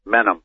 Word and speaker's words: Menem: Menem. – There you go. That's a better pronunciation.